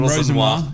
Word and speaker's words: Rosenior: Oh, Rosen, oh, Rosen, Rosenior.